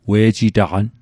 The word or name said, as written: we'jitaqan